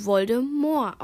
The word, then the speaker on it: Voldemort: Der Name des dunklen Lords stammt aus dem Französischen. Eigentlich müsste es also Voldemort ausgesprochen werden.